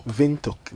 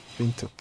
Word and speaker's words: Windhoek: Windhoek. Like this. Windhoek.